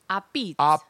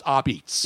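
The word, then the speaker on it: Apizza: It's called Sally's a pizza. – But it says a pizza. Right. But we know it's pronounced a-beats. – Yeah, you don't pronounce the A at the end. It's a pizza. – A pizza. It's like a pizza, not coffee. A P-E-E-T-S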